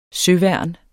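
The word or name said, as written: søværn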